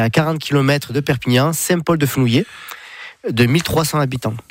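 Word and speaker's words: Saint-Paul-de-Fenouillet: Saint-Paul-de-Fenouillé, c'est comme ça que vous le prononcez. – Ouais, c'est ça.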